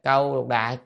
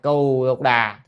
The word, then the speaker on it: Cù Lục Đà: À, hay là câu lục đà, cù lục đà, thì nên tiền trang thấy tra từ điển như kỹ thì nên đọc là cù lục đà.